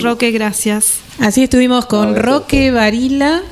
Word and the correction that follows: Barilá: ¿Varila o Varila, Roque? – Ah, Barilá, con acento en la.